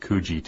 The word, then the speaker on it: Coogee: So how would you pronounce that second beach, C-O-O-G-E-E? – I would say Coogee. – I would have said Coogee, too.